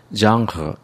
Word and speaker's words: genre: 발음이 약간 이상하죠. 왜 그럴까요? 프랑스 말에서 왔기 때문에 그렇습니다. 프랑스 사람들은 이걸 genre 이렇게 읽습니다.